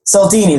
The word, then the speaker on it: Cialdini: How do you say that guy's last name? I'm not sure how you say it, but I think Cialdini. – That's it.